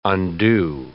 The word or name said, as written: undue